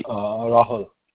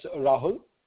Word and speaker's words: Rahul: It's Rahul. – Rahul? – Basically, the way it gets confused most of the time with the Spanish name Raul, but it's basically...